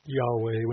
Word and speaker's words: Yahweh: It's four Hebrew letters transliterated in English, YHWH, which we pronounce as Yahweh.